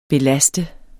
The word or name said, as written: belaste